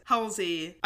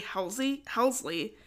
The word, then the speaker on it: Helsley: So if at any point during this episode says Helsey, I apologize. – Just ignore it. – I have to like look down at her name because it's spelled Hesley. Like it's very clearly Helsley.